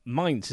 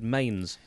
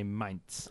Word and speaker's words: Mainz: Mainz, is that how it's pronounced? – Yeah. – I always would have said Mainz. – Yeah, it does look like that, but it's, you've got to have that German inflection about you to say Mainz.